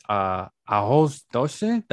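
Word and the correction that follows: Arroz doce: That's how you say it in Portuguese. – Arroz Dos. Yeah. – There we go.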